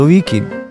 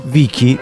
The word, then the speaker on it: Vichi: Mi chiamo Corrado Vichi. – Corrado Vichi. – Pronuncia, fai anche lo spelling. – V-I-C-H-I.